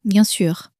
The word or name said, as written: bien sur